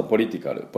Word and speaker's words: political: 先ほどから何度も出ていきます、ちょっとカタカナ英語でいきますと、ポリティカル、ポリティカルですね。これはポリのところにアクセントがありまして、どういう感じになるかというと、ポリティカル、ポリティカルなんですね。でポリティカルと書いてありますが CAL、 P-O-L-I-T-I-C-A-L なんですけども、このカルのところは A はほとんど発音しません。